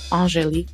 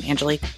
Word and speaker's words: Angelique: pronounces my name the way I love it. Doesn't Angelique just sound so much better than Angelique?